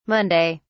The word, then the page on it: monday: British English